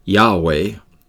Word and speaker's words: Yahweh: So sometimes it's pronounced Yahweh with just two vowel sounds, and other times Jehovah with three vowel sounds.